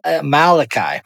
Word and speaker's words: Amaleki: Wait, what did you call him? – Amaleki. How do you say it? – Amalekai. – Amalekai. But I kind of like Amaleki. – Amaleki. – I like Amaleki too.